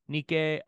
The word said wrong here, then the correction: Nika: And I said that wrong. Nika, not Nike.